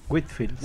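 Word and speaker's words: Whitefield: ¿Lo dije bien? – Whitfield.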